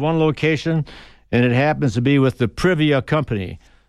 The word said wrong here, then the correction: Priva: That's Priva. So phonetically it's spelled Bravo Romeo India Victor Alpha. It's an in-sync dishwasher, it's called. There's also a foreign entity, Fotil, F-O-T-I-L-E, that also markets this type of a product.